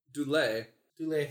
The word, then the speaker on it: Dulé: Dual Hill or D-U-L-E with... – Dulé Hill.